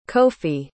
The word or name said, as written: kofi